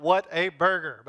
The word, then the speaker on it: Whataburger: I know it's spelled like what a burger, but it's pronounced Whataburger.